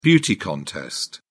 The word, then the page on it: beauty contest: British English